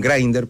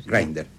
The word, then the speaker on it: Grindr: Teniente, puede ser también que utilizan Grindr, porque así se pronuncia, nos acaban de corregir y le queremos agradecer. – Grindr.